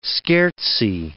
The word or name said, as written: scherzi